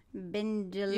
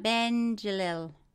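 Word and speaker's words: Bendjelloul: Bendjelil. You give it a, come again? – Bendjelil.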